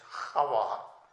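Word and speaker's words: Hava (חוה): And the Hebrews pronounce it with a CH, Hava.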